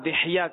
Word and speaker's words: دحیہ: حضرت سیدنا دحیہ قلبی رضی اللہ تعالیٰ عنہ، صحیح تلفظ یہ دحیہ دال پر زیر دحیہ بھی ہے، صحیح تر یہ کہ دحیہ.